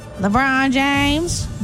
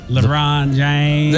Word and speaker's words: LeBron: No, you got to say it like the kid. – Try it. – LeBron James. – There. LeBron James.